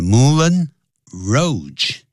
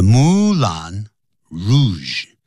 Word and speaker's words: Moulin Rouge: That is actually a French expression, so the pronunciation is not the same as you may think. The Moulin Rouge. Instead of saying the Moulin Rouge, no, this is not the way these two words are pronounced. You should say, The Moulin Rouge.